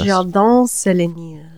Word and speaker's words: Un Jardin sur le Nil: The first of which is Hermes Un Jardin Sur Le Nil. I probably fucked that up. This was not a pronunciation podcast. – Un Jardin Sur Le Nil.